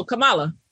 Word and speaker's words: Kamala: No, Kamala. – There you go. Kamala Harris.